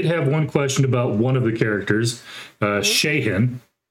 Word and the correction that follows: Shaheen: I hope I'm saying that right. – Yeah. Shaheen. – Shaheen. Okay.